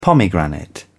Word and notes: pomegranate: British English

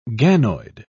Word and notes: ganoid: British English

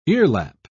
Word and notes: earlap: British English

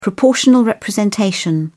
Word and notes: proportional representation: British English

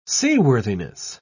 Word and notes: seaworthiness: British English

